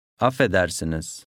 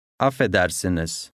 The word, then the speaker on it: Affedersiniz: Affedersiniz. – بعد از اینکه مخاطب جواب مناسب داد، سعی کنید اونو تکرار کنید. سعی کنید تلفظتون شبیه اون باشه. – Affedersiniz.